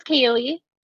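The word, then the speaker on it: Kali: My name is Kaylee.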